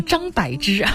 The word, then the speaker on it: 张柏芝: 上半段快要结束的时候呢，有一个朋友说就是想要知道跟柏芝对戏的时候啊，当然了，这个他的名字的标准的读音应该是张柏芝啊，对，为了就是让大家知道我说的是谁啊。总之了，不管是在这个语音当中的，那我们用张柏芝也好，用张柏芝也好，跟他对戏是传说当中的粤语和普通话无缝衔接吗？